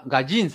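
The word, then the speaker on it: gaijin: una este especie de, Porque me acuerdo yo, por ejemplo, cuando estaba eh, en Japón, me decían gonji, que era como especie de forastero, ¿cierto? No, lo pronuncio bien de repente. Ah, gallín será. Gallín, me decían gallín. Gallín, Gajin. gallín.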